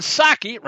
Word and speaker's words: Psaki: Jen Psaki, remember folks, the P is silent like it is in Resident,